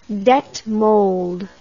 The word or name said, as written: detmold